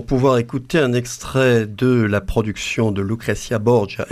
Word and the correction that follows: Lucrezia Borgia: Est-ce que je prononce bien, David ? – C'est Lucrezia Borgia. – Vous avez vu comme je ne prononçais pas bien.